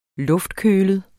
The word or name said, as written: luftkølet